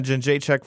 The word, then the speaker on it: Jędrzejczyk: And I saw you guys watch the one for the Joanna Jędrzejczyk fight. I know I just butchered her name. – Gesundheit. – But, yeah, man, I saw You said that pretty well.